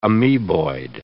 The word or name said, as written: amoeboid